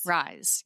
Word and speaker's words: Rhys: Jean Rhys. – Rhys, yes.